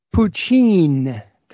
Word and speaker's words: Putin: And he was mentioning one thing about something that Putin, and he calls him Vladimir Putin. Putin. That's how to pronounce it.